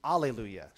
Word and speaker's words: alleluia: But then when they transliterate it, kind of just smush that into Greek, they do alleluia. So when you sing a song that says alleluia, they're kind of basing it off the Greek pronunciation. If it's hallelujah, it's kind of based off the Hebrew.